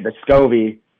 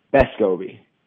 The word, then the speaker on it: Vescovi: And the way that we've been saying it, Vescovi puts the emphasis in the back two syllables, specifically the second one. So my understanding is it's Vescovi.